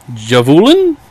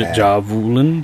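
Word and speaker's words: Jävulen: For Javulen, DJ A with an umlaut VULEN, And the A is pronounced as in San. – San. So Javulen. Javulen.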